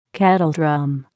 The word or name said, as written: kettledrum